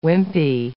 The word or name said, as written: wimpy